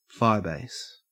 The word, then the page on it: firebase: Australian English